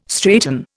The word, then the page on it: straighten: Indian